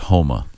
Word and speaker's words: Houma: The city is pronounced Houma.